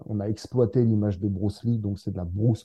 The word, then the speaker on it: Bruce Lee: Ça se prononce Bruce Lee à l'américaine.